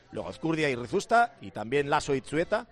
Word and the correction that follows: Iztueta: No, Itzueta lo digo mal, es Iztueta, ¿eh?